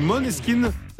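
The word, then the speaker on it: Måneskin: Et puis là, c'est Moneskin même si ça se dit Moneskin, ça s'écrit Maneskin.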